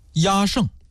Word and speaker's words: 压胜: 压胜，这个压胜的压呀，就是讨厌的厌，胜呢就是胜利的胜。我们说这个压这个音啊，有的时候念成厌也是没错的。